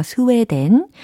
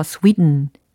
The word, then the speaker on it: Sweden: 그런데 과연 영어적으로는 어떻게 발음이 되느냐? 바로 이겁니다. Sweden, Sweden, Sweden. 오, 좀 다르죠? Sweden, Sweden. 아, 특히 이 끝부분의 소리 들으셨어요? 물론 모음도 스웨가 아니라 스위 위 이렇게 들렸으면서 동시에 된 이게 아니라 음 이렇게 약간 글로털 사운드라고 하거든요.